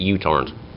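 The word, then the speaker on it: UTorrent (µTorrent): It's Ludwig Strigius said, wrote it as Mu, but it's he more usually pronounced it UTorrent. Mu is in micro. Mu is in microtorrent. Microtorrent's also a thing, but UTorrent is the usual accepted pronunciation.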